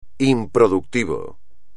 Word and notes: improductivo: espagnol

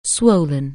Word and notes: swell: anglais

